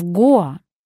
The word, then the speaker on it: Гоа: Ударение падает на гласную О. Правильно говорить в Гоа.